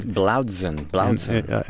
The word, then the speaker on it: Blaudzun: B-L-A-U-D-Z-U-N. – Blaudsen.